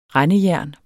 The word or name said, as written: rendejern